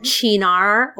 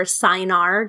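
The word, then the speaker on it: Cynar: And I am using Chinar or Sinar, depending on if you know how to pronounce it properly.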